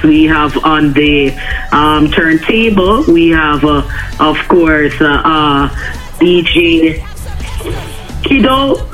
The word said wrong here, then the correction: Sticko: Am I saying the name properly? Sticko, DJ Sticko, there we go, DJ Sticko.